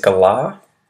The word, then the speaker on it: galah: The next word is galah. Galah. And a galah is it's an Australian parrot and it's spelt G-A-L-A-H.